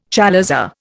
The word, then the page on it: chalaza: Indian